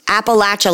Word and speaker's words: Appalachia: If you never, if you're like, I don't know how to pronounce that word, it's Appalachia, like I'll throw an apple at you.